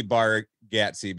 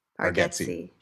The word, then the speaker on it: Bargatze: It looks like it would be Bargatze because it has an A, but it's pronounced get like G-E-T. – Bargatze.